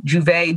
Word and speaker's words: Jouvay: Juve, J-O-U-V-A-Y, spelled phonetically, not the French way.